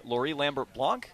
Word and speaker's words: Laurie Lambert-Blanc: We are joined now by Laurie Lambert-Blanc. – Yes. – Am I saying that right? – Perfect.